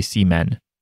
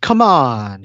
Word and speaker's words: C-Moon: Yeah, so it's this thing where Jolyne is like choking C-Moon and uh by the way, every time I say C-Moon, I think I'm going to say Seamen, but I don't. You're all lucky. Just it's pronounced come on. It's true. It's not Seamen, it's come on.